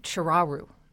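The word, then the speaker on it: Ciuraru: Well, one book that has come out this summer that I've enjoyed very much, it's called Nom de Plume by Carmela Chiraru, I think is her name. I had to go to her publicist to find out how to pronounce that. It's sort of like Chihuahua.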